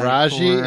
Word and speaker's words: Taraji: Taraji and Layla.